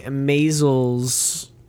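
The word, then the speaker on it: Maysles: We went into the Maisels, did I say that right? – Yeah.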